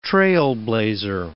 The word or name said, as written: trailblazer